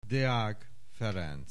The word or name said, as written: Deak Ferenc